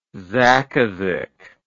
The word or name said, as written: zaqaziq